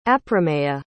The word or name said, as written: aprameya